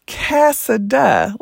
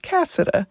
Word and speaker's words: Casada: And it's Casada, like Canada with an S, Casada.